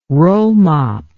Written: rollmop

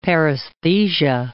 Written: paresthesia

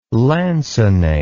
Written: lancinate

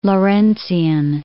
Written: Lawrencian

